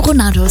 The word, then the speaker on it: Ronaldo: Vielleicht haben wir Cristiano Ronaldo seit Jahren falsch ausgesprochen. Er sagt nämlich selbst Ronald. Ja, also ohne O irgendwie.